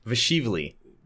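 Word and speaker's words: Dvalishvili: So let's start with Dotson versus Davashivla. Is that how you say it? How do you say it? – It's Vashivli. – Vashivli. – Marab. – The D is silent.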